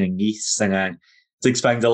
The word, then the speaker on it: Nice: Yeah, I'll probably butcher this, but I believe it was Nice. I think that's how you say it.